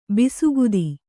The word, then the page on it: ಬಸುಗುತ್ತ: basu gutta